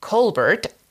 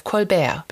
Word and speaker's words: Colbert: What she did do during this period was to pronounce her name differently Colbert instead of Colbert, because she really did not like to be typecast as the French maid, which happened during the early days.